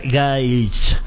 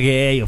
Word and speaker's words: Gaich: Gaich. Ah, así se pronuncia. – Gaich. – Ah, ¿con qué así? – ¿Pueden decir Gaich? Okay. O Fer Gay, o Fer Gay, como ustedes. La verdad es que como ustedes quieran y prefieren, como se sientan más cómodos.